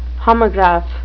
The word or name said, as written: homograph